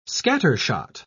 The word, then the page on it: scattershot: British English